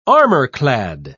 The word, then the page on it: armor-clad: British English